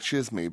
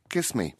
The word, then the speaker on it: chisme: And he discovered a variety of plant insects that he named after the Greek suffix C-H-I-S-M-E, which sounds like shizme, but it's actually pronounced kiss me.